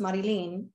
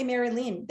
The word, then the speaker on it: Marylin: I'm obviously like Marilyn, but it's spelt M A R Y L I N. Mm. Look, mum, mum, South American, it's pronounced Marilyn, which I get, but growing oh, up, I'm like in school, you fucking know me, teacher, and yet you still say Mary Lynn. There's no hyphen.